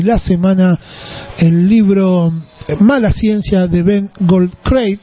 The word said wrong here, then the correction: Goldacre: sí, Goldacre, ¿sí? Así se escribe. Mala ciencia, entonces, de Ben Goldacre, o Goldricri, o algo así. Vamos a decirlo bien, Ben Goldacre, ahí está.